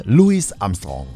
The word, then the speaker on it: Louis Armstrong: Er wird englisch ausgesprochen mit S hinten aussprechen. Ne? Louis Armstrong.